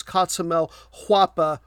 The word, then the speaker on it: Cotzumalhuapa: And just in case there's any question about the pronunciation I always try to get those. The town is Guapa, but the overall archaeological area or that region is Cotzumalhuapa with an H, different spelling.